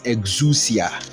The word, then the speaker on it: exousia: So the word all authority, the word authority in the Greek is exousia. Let me spell it for you. E-X-O-U-S-I-A. E-X-O-U-S-I-A.